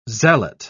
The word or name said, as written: zealot